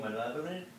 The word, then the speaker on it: malevolent: Malvolent. – Malvin. – Say that. – Oh, malevolent.